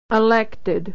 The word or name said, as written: elected